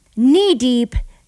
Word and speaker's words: Knee: I'm going to close up my show today with a silent K, Knee Deep, Zach Brown Band.